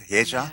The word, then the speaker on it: Hye-ja: Kim Hye-ja. Is that how you pronounce it? Hye-ja? – Yeah.